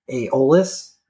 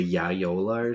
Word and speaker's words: Aeolus: So they get up close, and then they hop onto the cruise ship, and you find out that it's called the Aeolus. Mm-hmm. I think that's how you pronounce it. – Yeah. – And it was funny how Victor tried to pronounce it, the Yayola or something like yeah.